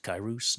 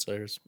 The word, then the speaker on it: Cyrus: I'm trying to remember because the only Persian kings that I remember are Cyrus, but pronounced Kairos, not fucking Cyrus. We just pronounce it Cyrus, but whatever.